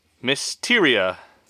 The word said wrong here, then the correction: Mycteria: Jabiru Mycteria.